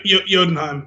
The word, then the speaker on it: Jotunheim: How do you say that? – Jotunheim. – Jotunheim? From conquering Earth.